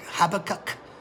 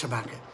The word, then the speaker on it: Habakkuk: Looks like Habakkuk, but it's pronounced Habakkuk.